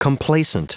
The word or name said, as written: complaisant